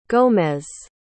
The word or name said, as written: gomez